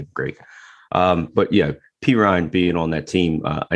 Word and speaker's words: Perine: but to pick up Perine, how do you say Perine? – Perine. Almost sounds like I'm saying it wrong by saying it right.